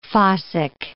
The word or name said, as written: fossick